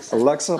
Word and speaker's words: Alexa: I say, Alexa, plug one. – The stress is on the middle. Alexa.